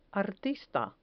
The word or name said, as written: artista